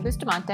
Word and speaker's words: Bustamante: So, like you said, my name is Cassie Bustamante, and I commend you on the pronunciation there. Very good job.